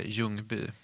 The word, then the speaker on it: Ljungby: Eller Ljungby, som man uttalar, tror jag.